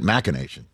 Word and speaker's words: machination: But I pronounce it machination. There was a machination right there.